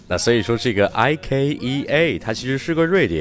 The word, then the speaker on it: IKEA: 那很多人会把宜家的英文读成 IKEA，甚至有些人还会读成 IKEA。那很明显，以上都是错的。它在英文中真正的读音应该是 IKEA，IKEA。那前面的字母 I 其实就是跟 iPhone 的 I 是一样的发音的。